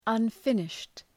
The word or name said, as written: unfinished